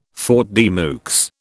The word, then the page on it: faute de mieux: British English